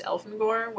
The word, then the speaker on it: Elfangor: and a little bit Elfanger as well, which we just found out when we were Wikipedia-ing pronunciations that K.A. herself has said that it's pronounced Elfangor, which...